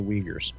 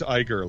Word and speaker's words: Uyghur: What are the odds that they'd be the exact same place as the Uyghurs? I'm just saying. – Is that how you pronounce it? – Uyghur. – I thought it was Iger, like the former CEO, Bob Iger.